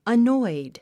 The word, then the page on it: annoyed: ənɔ́id; アノイド